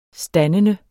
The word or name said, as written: standende